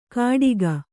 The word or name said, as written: ಕಟಿಗ